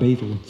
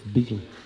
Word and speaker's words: betel: So it's like, beetle. – Like the Beatles? – It's not. Yeah, like the Beatles. Not betel. Sometimes they'll just say betel. It's beetle. So anyway, so in the part of the evening, I was just mentioning they're eating, of course.